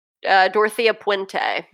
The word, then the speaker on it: Dorothea Puente: How do you say her last name? – Dorothea Puente.